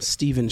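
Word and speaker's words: Steven: And our special guest, I am Stephen Strickland.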